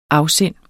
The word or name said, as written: afsind